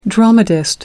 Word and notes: dramatist: American English

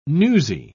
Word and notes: newsie: British English

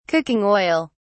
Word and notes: cooking oil: British English

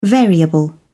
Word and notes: variable: British English

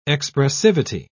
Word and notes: expressivity: British English

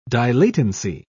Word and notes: dilatancy: British English

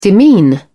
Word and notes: demean: British English

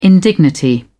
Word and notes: indignity: British English

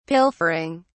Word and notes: pilfering: British English

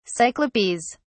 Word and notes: cyclopes: English; British English

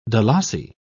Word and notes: dalasi: British English

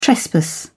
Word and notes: trespass: British English

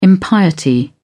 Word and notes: impiety: British English

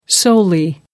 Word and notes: solely: American English

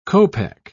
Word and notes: copeck: British English

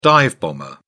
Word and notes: dive-bomber: British English

